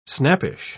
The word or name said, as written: snappish